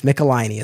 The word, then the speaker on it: Michelinie: I looked up a YouTube video where he said his name because I wanted to make sure I had the pronunciation correct. So uh, it's, it's, it's Michelinie as far as I understand it.